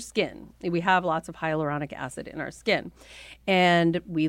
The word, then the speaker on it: hyaluronic acid: I want to know in particular about hyaluronic acid. – Am I pronouncing that correctly? Yeah. Because hyaluronic acid is, it's certainly a great ingredient.